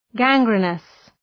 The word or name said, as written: gangrenous